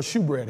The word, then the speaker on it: showbread: Or shoebread, however you want to say it. Hebrew pronunciation is showbread, all right?